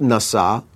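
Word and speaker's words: Nasah (נשה): There is yet another family word that I want to introduce to you, and it is pronounced Nasah, spelled Nun Sin He, and it means to forget.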